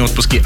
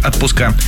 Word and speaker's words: отпуска: Отпуска. Все-таки не отпуски. Отпуска.